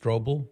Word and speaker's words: Strobel: I really respect your opinion and your knowledge, and I have been reading or listening to the book by Lee Strumbell. Did you ever know anything about him or read any of his writings? Or he's a Protestant apologist. I've always heard his name pronounced Strobel, but then again, maybe.